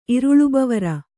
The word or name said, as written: ಇರುಳುಬವರ